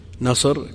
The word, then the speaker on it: نصر: نصر كذا تنطق بالصاد وبدون أل بخلاف النضر بالضاد فإنها تنطق بأل